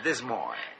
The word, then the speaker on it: Des Moines: I never know how to pronounce it myself. Do they say Des Moines in America? Is that how they pronounce it in America? – Des Moines. It's a great place, Des Moines.